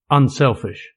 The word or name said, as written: unselfish